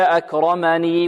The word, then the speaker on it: أكرمني: إذا وقفنا عليها هكذا: فيقول ربي أكرما، وإذا وصلناها هكذا: فيقول ربي أكرمني وأما أكرمني وأما، بارك الله فيكم.